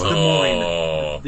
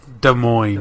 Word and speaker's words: Des Moines: It's Des Moines. The S is silent. – Des Moines.